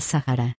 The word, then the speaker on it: Sahara: La palabra Sahara viene del árabe y significa desierto, la pronunciación más parecida en español sería Sahara.